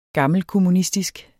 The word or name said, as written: gammelkommunistisk